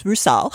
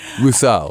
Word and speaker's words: Rusal: Well, it's Rusal. – Rusal. – Yes. – My Russian pronunciation.